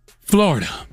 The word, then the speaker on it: Florida: and that's Florida. Is Florida two syllables? Three, right? Florida. Okay. When the reality is the only logical reason for her behavior is one word, three syllables, and that's Flo-ri-da.